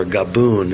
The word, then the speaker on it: Gabon: One's about Senegal, one's about Gabon or Gaboon, as it's sometimes pronounced in English.